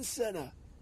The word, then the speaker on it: Senna: Ayrton Senna. Rhymes with tenor.